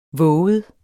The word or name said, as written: vovet